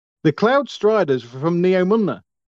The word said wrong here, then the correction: Neomuna: No, Neomuna.